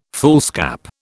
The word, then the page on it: foolscap: British English